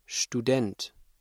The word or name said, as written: Student